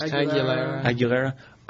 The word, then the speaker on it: Aguilera: Aguilera.